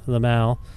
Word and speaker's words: Lamal: And I want to talk a little bit about this record, Lamal. Oh, sorry. How do you pronounce your record's name? My French is... I won the French award in grade 8. – You pronounce it right.